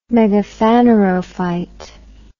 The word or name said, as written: megaphanerophyte